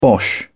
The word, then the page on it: bosh: British English